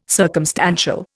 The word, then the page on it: circumstantial: Indian